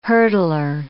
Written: hurdler

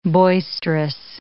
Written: boisterous

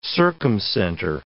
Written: circumcenter

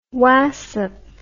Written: wassup

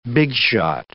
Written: big shot

